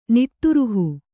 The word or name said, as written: ನಿಟ್ಟುರುಹು